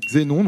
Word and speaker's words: Xénon: Xénon, non, ça se prononce bien X. Il faut que le X se prononce Z.